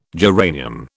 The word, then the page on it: geranium: British English